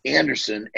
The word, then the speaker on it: Andersen: It's J-O-N, no H. So J-O-N hyphen, not to be confused with the underscore, Anderson, A-N-D-E-R-S-E-N.